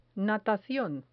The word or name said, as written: natacion